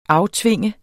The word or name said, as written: aftvinge